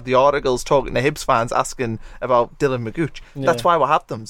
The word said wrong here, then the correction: McGeoch: So do you say it Magooch? Is that how you say it? – I don't know. – No, it's McGeoch.